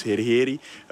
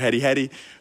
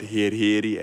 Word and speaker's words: herheri: Want het gerecht heet dus herheri. Je mag het uitspreken zoals je zelf maar wil. Sommige mensen roepen het herri-herri. Maar wij noemen het herheri.